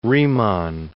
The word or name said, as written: Riemann